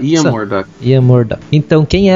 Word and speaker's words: Ian Murdock: Olha, eu diria uh, Ian Murdoch.